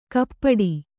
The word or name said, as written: ಕಪಾಟೆ